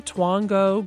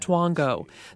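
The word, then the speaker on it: Twongo: Now your mom and Aunt Daisy can just sit back with their smartphones and wait for Twango, Groupon, Living Social, or dozens of other sites to email them the deal or the coupon of the day.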